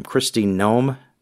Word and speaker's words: Kristi Noem: How do you say her name? Kristi Noem. Do I have that right? – Yeah, I think that's right.